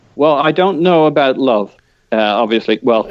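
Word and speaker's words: Löw: Love.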